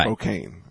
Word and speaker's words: Spokane: Spokane, Washington. – That's how you say it, right? – Right. – Spokane.